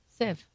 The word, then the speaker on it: sieve: Sieve. Yeah, no, it's definitely sieve.